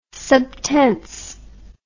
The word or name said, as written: subtense